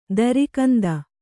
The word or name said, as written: ದರಿಕಂದ